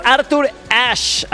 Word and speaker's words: Arthur Ashe: Oye, nos está escuchando nuestro querido amigo Ricardo Cázares, que me manda mensajito y nos dice: pollito, es Arthur Ashe, así se pronuncia, y es el estadio de Estados Unidos en New York, se llama así.